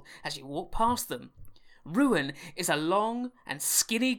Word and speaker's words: Ryun: Introducing Ruin, R-Y-U-N, should be pronounced Ruin.